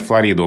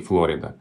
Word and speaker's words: Флорида: Названия Амстердам, Анкара, Белград, Вашингтон, Манчестер, Панама, Хиросима, Флорида. Все они произносятся по-русски не так, как в языке источники. Ну вот, пожалуй, пример Флорида общеизвестен, да, что по-английски Флорида.